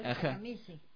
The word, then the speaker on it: Takemitsu: ¿Cómo pronunciar Takemitsu? Bueno, en el japonés hay que leerlo como está cuando no se conoce el idioma.